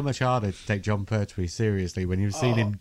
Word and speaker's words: Pertwee: I said Pertwee.